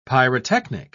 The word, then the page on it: pyrotechnic: British English